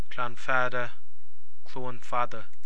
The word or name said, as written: Cluain Fada